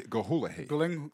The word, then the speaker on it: Gyllenhaal: Maggie Gyllenhaal. Yeah, that's how they say they pronounce her last name. – Oh, Gyllenhaal.